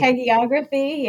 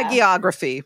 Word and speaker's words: hagiography: It's really a how do you say that word? – Hagiography. Yeah. – Hagiography. Right.